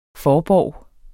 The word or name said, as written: forborg